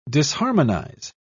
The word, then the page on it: disharmonize: British English